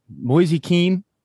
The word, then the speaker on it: Moise Kean: Moise Kean, is that how you say it? – Yeah, the Italian.